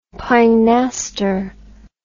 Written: pinaster

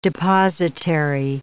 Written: depositary

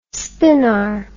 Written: spinar

